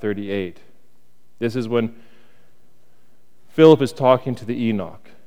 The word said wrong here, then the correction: eunuch: Is that what it is? Enoch? Yeah. Eunuch, that's it. Thank you. I know I was saying it wrong.